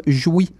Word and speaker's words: Jouy: Donc, la formation se nomme Jouy. Et c'est vraiment comme ça que ça se prononce. J'ai fait des recherches, chers amis. Alors, ça se nomme Jouy, dans le sens de jouissance.